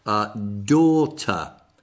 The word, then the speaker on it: daughter: allora, io vi dico come è pronunciato, perché lascia perdere come è scritta. Daughter. Come porta do. Non pronunciando le R finali.